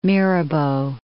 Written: Mirabeau

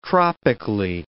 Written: tropically

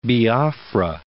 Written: Biafra

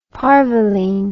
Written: parvoline

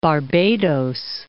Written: Barbados

